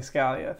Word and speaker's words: Scalia: So the correct way to say it is the way he pronounced it, which is Scalia. – Uh-huh. – But to distance myself from him, I say Scalia. – There you go. – I say it the wrong way on purpose.